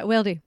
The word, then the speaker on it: Wildy: Yeah, Wildley. – Wildley. Yeah. Wow. How do you spell it? – Wildley. Like, you're right. – Is it like W-I-L-E? – W-I-L-D-Y.